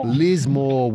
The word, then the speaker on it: Lismore: And the name of this country town, which is a big regional hub of northern New South Wales, it starts with L and it rhymes with floor. So thank you. – Yep. – Lismore. – Well done. All right.